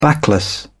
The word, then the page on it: backless: British English